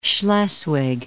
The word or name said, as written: Schleswig